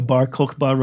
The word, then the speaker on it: Bar Kokhba: The Bar Kokhba revolt.